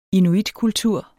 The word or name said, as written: inuitkultur